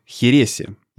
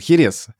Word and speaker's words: Херес: И напиток, э, который делают там, она называет тоже херес. При том, что, конечно, для русского уха э, и для жителей России привычнее называть это хересом. Но она, опять же, поскольку она туда переехала, и она там э, ассимилировалась, да, она произносит херес так, как это произносят все в Испании.